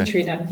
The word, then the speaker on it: Katrina: Katrina.